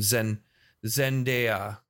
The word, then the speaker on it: Zendaya: She just goes by one name, Zendaya.